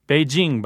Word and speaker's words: Beijing: Beijing, by the way, is the most correct pronunciation.